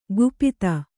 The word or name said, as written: ಕುಪಿತ